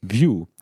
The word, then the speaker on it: Vue: Und ich habe nochmal gerade in die Dokumentation reingeguckt und der erste Satz ist Vue, in Klammern, pronounced like Vue. Also wie ein UI-View zum Beispiel.